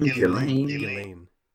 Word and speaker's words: Ghislaine: And now you got, how do you pronounce her name? Is it Ghislaine? – Ghislaine.